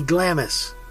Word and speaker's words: Glamis: Now, when you read about this castle, you read the spelling G-L-A-M-I-S, which to me, here in Texas, would be Glamis, but it's not. It's pronounced Glamis.